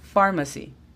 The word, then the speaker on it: pharmacy: La escribiríamos F-O-U-N. Phone. Por último, tenemos la palabra pharmacy. Igual, pareciera que tenemos la letra F, pero realmente pharmacy se escribe P-H-A-R-M-A-C-Y. Pharmacy. Porque la P está a la par de la H, se convierte en sonido de F. Pharmacy.